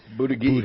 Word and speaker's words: Buttigieg: Why, how do you get that out of this? That isn't how it's spelled. – Buttigieg. – But that's how it's pronounced. If you Google it, you can listen to it in audio.